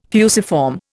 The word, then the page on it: fusiform: Indian